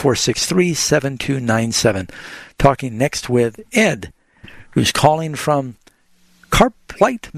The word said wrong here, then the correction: Carp Lake: Michigan. Am I saying that right? Ed, you're on the air with Bible Answers Live. – Yeah. Carp Lake is a fish. – Oh, okay. – The fish, carp.